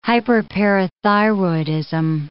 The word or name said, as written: hyperparathyroidism